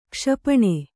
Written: ಕ್ಷಿಪಣಿ

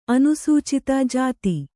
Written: ಜತಿ

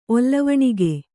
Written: ಒಲ್ಲವಣಿಗೆ

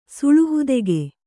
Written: ಸುಳುಹುದೆಗೆ

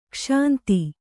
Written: ಕ್ಷಾಂತ

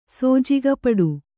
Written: ಸೋಜಿಗಪಡು

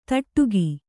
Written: ದಡಿಕೆ

